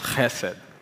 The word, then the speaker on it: chesed: So one of the Hebrew words for love is hesed, or should be pronounced chesed, which is actually a difficult word to translate in English, thus the many translations.